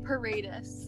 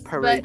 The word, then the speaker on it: Paredes: Paredes. Paredes. But if you want to, like, less white it, it's, like, Paredes, like, walls.